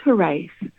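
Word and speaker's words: Hiraeth: Am I saying this correctly? It's pronounced Hirath? – Hirath. Yeah, is the way.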